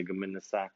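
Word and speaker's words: gomenasai: And I kept saying it wrong though. I was like, gomine, I'm supposed to say gominasai. And like Tade said, gominasai.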